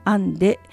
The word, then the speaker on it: and.e: アンデ、 and.e と書いてアンデと読む当社のオリジナルブランドです。